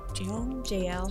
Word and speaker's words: Jael: And then like later on, like Sisera goes to, I don't know how to say her name, Jael. – Jael.